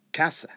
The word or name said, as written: casa